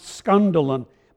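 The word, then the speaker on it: skandalon (σκάνδαλον): In Greek, it's pronounced skandalon.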